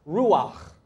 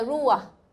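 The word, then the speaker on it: ruach (רוּחַ): It's from this word that means breath, and it's from a Hebrew word which is pronounced ruach. – 就是希伯来文的 ruach.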